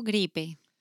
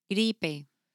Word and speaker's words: gripe: Gripe. – How do you spell that? – G-R-I-P-E. – Gripe. So it's like a gripe.